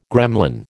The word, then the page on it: gremlin: American English